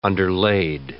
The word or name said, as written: underlaid